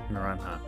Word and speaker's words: Naranja: Maybe that's pronounced Naranja because it looks like the Spanish word for an orange, like the fruit. Naranja.